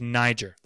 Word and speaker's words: Niger: Simeon is called, his nickname is a word that you have to be very, very, very careful around, and that is Niger. That's a long I and a soft G.